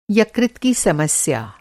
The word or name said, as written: यकृत